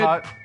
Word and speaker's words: Tutvid: Tutvid. – It's spelled T-U-T, Tutvid.